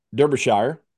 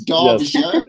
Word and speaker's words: Derbyshire: Now you live in, and I'm gonna say it wrong, Derbyshire. – You did say it wrong. That's right. It's Derbyshire.